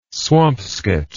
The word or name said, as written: swampscott